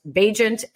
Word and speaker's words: Bagent: on the flip card for this week it says Bagent as an agent, so that everyone knows how to say his name.